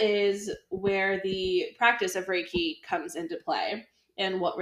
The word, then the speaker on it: Reiki: And today we are going to talk about something called Reiki. I am pretty sure that that's how you pronounce it. – Yep, This is from Japan, and it's basically an energetic healing process.